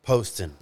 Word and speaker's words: Poston: Poston. – Poston. – A lot of people say Poston, but it's Poston.